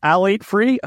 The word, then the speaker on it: phthalate-free: Phthalate-free? It's phthalate-free, I think. – Phthalate-free. P-H-T-H-A-L-A-T-E. That's a lot of consonants. – The P-H-Q and U are all silent in the Yeah.